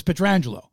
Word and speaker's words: Pietrangelo: We used to call him Pietrangelo. It's Pietrangelo from Niagara Falls. But this was back in the 80s, right? So it's like, oh, Frank Pietrangelo.